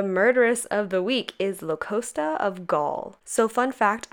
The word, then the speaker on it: Locusta: According to Google, it is pronounced Locusta. So I'm gonna be saying it, Locusta.